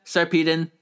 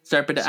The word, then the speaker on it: Sarpedon: Yeah, Sarpedon. Sarpedon. I don't. They only say it one time in the episode, so it's always. I always forget. – I like Sarpedon. – Yeah. – Sarpedon fights Aku again and wounds him this time.